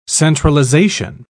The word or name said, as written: centralization